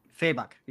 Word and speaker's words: Fabok: is it pronounced Fabok? Fabok, yeah, I believe.